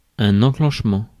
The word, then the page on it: enchantement: France; /ɑ̃.ʃɑ̃t.mɑ̃/